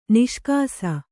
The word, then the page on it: ನಿಷ್ಕಾಸ: niṣkāsa; Kannada